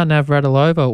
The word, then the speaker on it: Navratilova: Martina Navratilova won a record 9th Wimbledon singles championship.